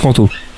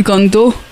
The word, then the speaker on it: Kanto: Personnellement, en français, on prononce Kanto. Ah, voilà. Euh, après, je sais absolument pas comment ça se prononce en japonais, donc je ne pas à ce niveau-là. Kanto. je parle un peu fort dans le micro. – Alors, pour la parenthèse, en japonais, ça c'est du Kanto.